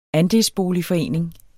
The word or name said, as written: ANDELSBOLIGFORENING